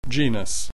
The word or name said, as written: genus